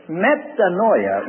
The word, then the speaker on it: metanoia: The Greek word that's translated repentance is metanoia. I'll spell it in English. M-E-T-A-N-O-I-A.